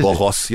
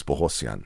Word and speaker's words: Bogosian: You know, it's like, it's Bogosian, Bogosian. – What is Bohosian. – It? – Well, the correct pronunciation is Bohosian.